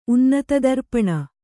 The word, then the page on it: ಉನ್ನತದರ್ಪಣ: kannada